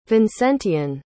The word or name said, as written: vincentian